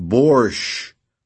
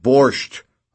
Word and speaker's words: borscht: "Kirill," I yell, "are you going to the borscht today?" "It's pronounced borscht, not borscht.